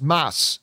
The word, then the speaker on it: Mads: Willow writes, I've just recently learned that the D in Mads Mikkelsen's name is apparently silent. So his name is actually pronounced Mass.